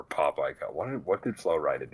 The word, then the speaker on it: Flo Rida: Florida? – Florida is his boss. – Mr. Florida. – No, it's Flo Rida. I would actually, come on, pronounce it right. – Oh, fuck. – Flo Rida and Florida.